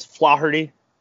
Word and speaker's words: Flaherty: Excuse me, you mispronounced that as Flaherty? Flaherty. – Sorry. – You got to roll that. Flaherty.